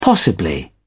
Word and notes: possibly: British English

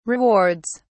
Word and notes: rewards: British English

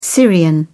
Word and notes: syrian: British English

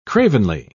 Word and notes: cravenly: British English

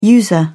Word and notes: user: British English

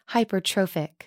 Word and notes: hypertrophic: American English

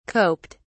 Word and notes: coped: British English

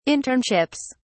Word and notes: internships: British English

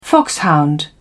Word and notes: foxhound: British English

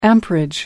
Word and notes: amperage: American English

